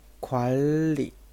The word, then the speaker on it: 관리: 관 리 这个单词需要注意，它有音变啊。本来是宽宽 리 但是这个地方有一个同化现象，要读成“ 管理” 啊，“ 管理” 管理的意思。